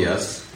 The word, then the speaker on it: Yess: So my name is Tali Yes. My last name is spelled Y-E-S-S. Second S is silent.